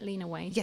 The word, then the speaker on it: Lena Waithe: I did, to Lena Waithe. Is that how you say it, Lena Waithe? – Yes, yes, yeah. You got it.